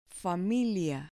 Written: familia

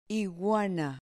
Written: iguana

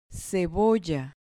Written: cebolla

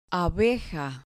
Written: abeja

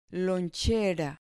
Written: lonchera

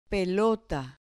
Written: pelota

